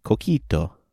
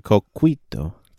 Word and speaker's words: coquito: What's it, how do you say it again? – It's Coquito. Coquito. Or Coquito.